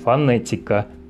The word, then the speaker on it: фонетика: Интернет, произносим «э», фонетика, снова «э»,